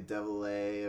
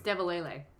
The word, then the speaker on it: DeVillers: It's like, isn't like DeVillers would be Devil-A or... – It's Devil-A-Lay. – Devil-A-Lay? – Mm-hmm. – All right, cool. I'm certain. – That's the official pronunciation.